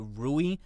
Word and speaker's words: Rue: Apparently she plays a character named Rue, R-U-E. I think some people pronounce it Rui, but I'm going to call her Rue for right now. You know, R-U-E, Rue.